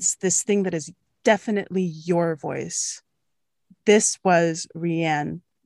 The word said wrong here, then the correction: Raehann: the Rayanne, damn it, I'm saying it wrong. this was Raehann Bryce Davis.